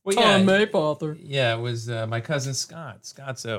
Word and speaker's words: Mapother: I thought it was Mapother, but apparently it's Maypother.